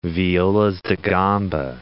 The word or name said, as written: violas da gamba